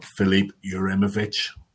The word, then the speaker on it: Filip Juranović: Filip Juranović.